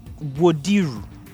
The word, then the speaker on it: Wodiru: They say it's Wodiru. – Wodiru.